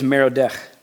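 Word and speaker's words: Merodach: Or sometimes it's a Hebrew pronunciation of Marduk, which is Merodech.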